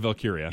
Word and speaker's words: Valkyria: Singles match, NXT Women's Championship match, Lyra Valkyra. I know I'm going to butcher that. – Lyra Valkyria. – Yeah, Valkyria.